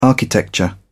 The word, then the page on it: architecture: British English